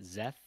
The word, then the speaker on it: Zeth: But are you telling me that they just pronounce that Zeth? – Yes. – Zeth. – They pronounce it Zeth as in a Z and then Eth. They just, Eth, yeah. – The S is silent is in the audiobook.